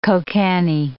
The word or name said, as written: kokanee